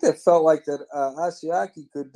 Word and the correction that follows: Asiasi: Is that how you say it? – Asiasi, yes.